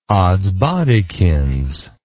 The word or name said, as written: oddsbodikins